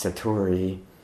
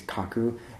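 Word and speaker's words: Satori (覚): That also, interestingly, in Japanese is pronounced Satori, and also means to realize or understand, but in its kind of Sino-Japanese reading is Kaku, and it maybe most commonly and literally means awake or awakening.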